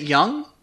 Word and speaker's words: Jung: Yep, Is it Jung? – Is it, yep. – It's, we had literally the same discussion last time. – Oh no! – It's J-U-N-G.